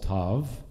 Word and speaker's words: Tov: All of these verses begin with the letter Tav, which is spelled T-A-U. It's not like Mazel Tov. That's Tov, T-O-V, Mazel Tov.